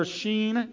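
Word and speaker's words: Shin: So let's get into tonight's letter, the letter Sheen. It can be spelled several ways, but it's pronounced S-H-E-E-N, Sheen.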